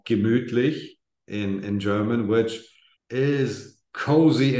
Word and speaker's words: Gemütlich: Gemütlich. Gemütlich.